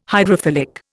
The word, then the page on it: hydrophilic: Indian